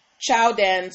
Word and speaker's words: Qiaodan: They call themselves, it's spelled Q-I-A-O-D-A-N Sports. And it's supposed to be the romanized version of Michael Jordan's name in Chinese. So essentially, this is supposed to be pronounced Chowdan Sports, Jordan Sports, right?